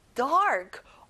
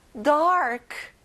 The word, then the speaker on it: dark: 어두운, dark. 자, r 발음이 나죠? 한 번 더, dark.